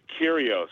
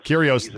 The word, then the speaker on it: Kyrgios: Nick Kyrgios. – Kyrgios, the bad, he is the bad boy of tennis.